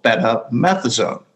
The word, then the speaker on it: betamethasone: betamethasone, which is..." – Say that again so I can learn how to say it because I'm going to need to say it. Betamethasone? – You got it.